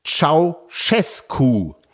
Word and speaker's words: Ceaușescu: Meine Lieben, der erschossene rumänische Diktator wird ausgesprochen wie Ceaușescu. Der ausgeschlossene Präsidentschaftskandidat wird ausgesprochen Georgescu. Das J jeweils weich, wie Georg im Englischen.